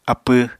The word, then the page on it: apw: Apatani